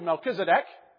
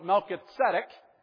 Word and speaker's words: Melchizedek: So we anglicize it to Melchizedek. I prefer to keep it more closely to its Hebrew, Melchizedek, which means King of Righteousness in Hebrew.